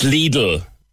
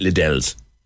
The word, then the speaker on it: Lidl: But there are many different ways to pronounce it. My mother-in-law had her own one entirely. She called it Lidl's. And we had no idea what she was talking about until she pointed to it.